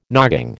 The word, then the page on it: nogging: American English